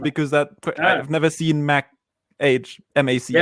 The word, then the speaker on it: Mach: And then I think there was another follow up on the question earlier about the Mac. Age. Is it Mac Age? How do you pronounce it? M-A-C-H? – M-A-C-H. Oh, Mac.